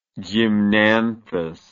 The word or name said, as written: gymnanthous